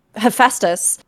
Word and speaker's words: Hephaestus: Hephaestus. – Mm-hmm. – Did I just say that right? Yes. Yes. Yeah.